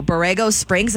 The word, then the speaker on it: Borrego Springs: Borrego Springs, am I saying that right? – You are.